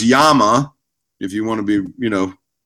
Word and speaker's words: Llama: And uh, and Llama pistols out of, uh, or you know, if you want to say it right, Yama pistols out of Spain. – Is that how you say? It? I was always wondering. I always call it La Llama because there's yeah. two L's.